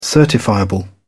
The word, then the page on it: certifiable: British English